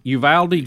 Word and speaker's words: Uvalde: Uvalde, just like it's spelled.